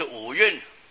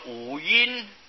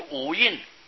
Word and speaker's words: 五蕴: 这里五蕴就是五蕴啊，不要念作五音啊，这是遮蕴的蕴同音啊，读破音啊，就五蕴啊，蕴是遮蕴遮盖的意思，被这五法遮盖了啊，真性显不出来。